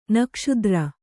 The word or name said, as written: ನಾಗಾಸ್ತ್ರ